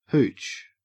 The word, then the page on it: hooch: Australian English